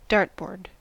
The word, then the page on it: dartboard: American English